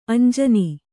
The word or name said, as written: ಅಂಜನಿ